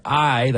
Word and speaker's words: Ahi: That's award-winning singer-songwriter I, that's spelled A-H-I, with Where I'm Coming From.